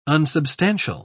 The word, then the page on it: unsubstantial: British English